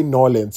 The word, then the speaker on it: New Orleans: So okay, some people call it New Orleans. Like literally, it's spelled New Orleans, but some people say Norlins. So like N A W L I N S.